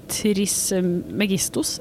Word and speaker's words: Trismegistus: Visions of Trismegistus? Er det det du sier? Trismegistus? – Ja. – Tribestigos? – Trismegistus. Det er ingen av oss som er grekere nok til å uttale det korrekt uansett, antageligvis.